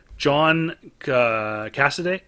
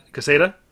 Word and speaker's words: Quesada: This is a John Cassidy. How do you say that? – Quesada? – Quesada.